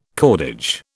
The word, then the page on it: cordage: British English